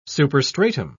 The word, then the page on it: superstratum: British English